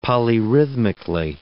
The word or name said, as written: polyrhythmically